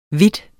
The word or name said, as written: vidt